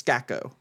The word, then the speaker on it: Skako: Yeah, so it's Skako, right? Ah. – Skako. – But it's an A. It's spelled with an A. – Skako. – Okay, like Gordon Gekko.